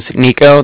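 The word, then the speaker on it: Niko: Okay, so like I said, my name is Nico. That's N-I-K-O. Mm-hmm. And then the Bank of Cardiff is spelled C-A-R-D-I-F-F, as in Frank Frank.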